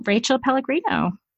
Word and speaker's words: Pellegrino: So let me just welcome Rachel Pellegrino. – Hey, thanks for having me. And I should have asked you, your name is pronounced like the fuzzy water. – It is exactly like that.